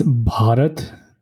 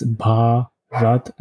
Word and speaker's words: Bharat: How would you pronounce it? – It's Bharat. It's Bharat, and I think it's going to be a little hard for you to pronounce.